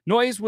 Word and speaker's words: Noyes: So it all began with a man named John Humphrey Noyes. N-O-Y-E-S. If you've always been wondering how to say it, it's Noyes.